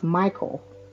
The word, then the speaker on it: Mickel: So I will tell you up front that people will hear this, and this is my married name, and it's actually pronounced Michael. It looks like Mickel, but it's actually pronounced Michael. So I get that all the time. So I answer to both, Mickel and Michael.